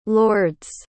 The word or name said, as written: lourdes